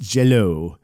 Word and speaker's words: Jello: But with the accenting, it's still pronounced jello.